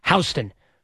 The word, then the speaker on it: Houston: It's spelled Houston, but it's pronounced Houston.